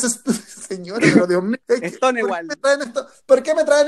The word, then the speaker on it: Stonewall: Estamos en, Chile y no puede pronunciarlo como queráis, pero se llama Stonewall, como muro de piedra. – Eso, pues Stonewall. Pues, de última, no es Stonen, es Stone, Stonewall.